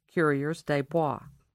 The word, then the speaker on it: coureurs des bois: The trappers were called coureurs des bois, pronounced coureurs des bois.